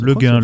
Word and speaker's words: Le Guin: Terre et Mer d'Ursula Le Guin, le gain, je sais pas comment euh, vous voulez qu'on prononce. – Le gain, le gain.